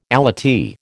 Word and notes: allottee: American English

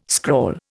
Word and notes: scrawl: Indian

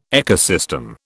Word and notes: ecosystem: British English

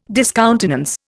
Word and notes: discountenance: Indian

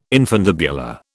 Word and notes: infundibular: British English

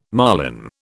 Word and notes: marlin: British English

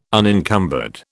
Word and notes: unencumbered: British English

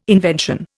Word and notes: invention: Indian

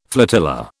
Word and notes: flotilla: British English

